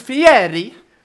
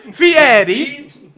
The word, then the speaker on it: Fieri: I'm gonna say Fieri every time you fuckheads disrespect him and say his name wrong. – Do you think Fieri? – Fieri. – Fieri. – It's Italian, you fuck.